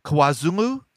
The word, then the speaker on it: KwaZulu: Um, talk about uh, is that pronounced KwaZulu? Yes, KwaZulu Natal.